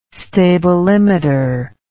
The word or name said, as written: stabilimeter